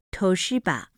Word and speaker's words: Toshiba: 在翻译时，前两个东芝按日语 Toshiba 发音，于是整句被年轻人开玩笑的用谐音的办法念成偷去吧，偷去吧，大家的东西，使其严肃性大降。